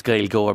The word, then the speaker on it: Gaelgoer: It's Gael Goer, but it's spelled in the English way, G-A-E-L-G-O-E-R.